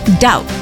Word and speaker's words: doubt: Yes, that's doubt. The B is silent.